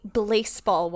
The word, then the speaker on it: Blaseball: I'm the Blaseball one. And yes, I did pronounce that with an L, Blaseball.